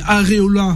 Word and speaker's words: Areola: Au poste de latéral droit, en défense centrale, où c'est fébrile, au poste de gardien de but, quand je vois Costil et Areola, voilà, c'est comme ça que ça se prononce.